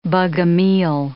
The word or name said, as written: Bogomile